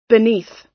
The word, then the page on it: beneath: American English